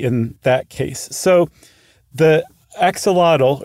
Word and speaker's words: axolotl: So the word axolotl, what we call axolotl, is actually axolotl, even though it's spelled the exact same way. In the Nahuatl language, A-T-L is pronounced lut, and X is very often pronounced sho. It can also be pronounced wa.